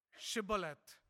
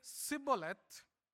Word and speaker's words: Shibboleth: And if they say no, then you shall ask them to pronounce Shibboleth. And if they say Sibboleth, then they know that this person is an Ephraimite.